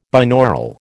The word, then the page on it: binaural: American English